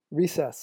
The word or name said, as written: recess